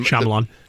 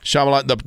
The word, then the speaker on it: Shyamalan: Night Shyamalan, or however you say his name. – Shyamalan. – Shyamalan.